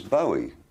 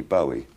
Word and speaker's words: Bowie: I always thought it was Bowie. I thought, well, it's a Scottish name, must be Bowie. But nobody in Scotland pronounces it like that. Pronounce it Bowie on it.